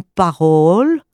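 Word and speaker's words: parool: Et qu'est-ce que c'est qu'une parole ? Une parole, je vous le prononce un petit peu avec le haut long, comme ça devrait se prononcer en néerlandais, c'est le mot de passe, c'est le slogan.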